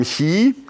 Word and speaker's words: Chi: kommt von dem griechischen Buchstaben Chi. Das schreibt sich wie ein deutsches X, so sieht das quasi aus. Wird nicht wie X ausgesprochen, sondern wie ich.